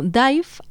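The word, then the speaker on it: Dàimh: aunque realmente se pronuncia como Dive, aunque se escribe Dime acabado con una H, que quiere decir afinidad o parentesco en gaélico.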